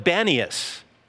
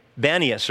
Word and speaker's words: Banias: But because the Arabs pronounce peas as bees, they call it Banias. And so that's what it's called today, Banias or Banias.